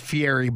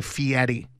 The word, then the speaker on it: Fieri: So is it Guy Fieri or Guy Fieri? – Well, everybody says Fieri, but the correct pronunciation is Guy Fieri.